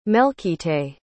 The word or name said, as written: melchite